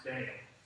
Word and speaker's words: Baal: But what happened is Jezebel was a staunch pagan, and she worshipped Baal, or you may even hear it pronounced Baal. It could be pronounced either way.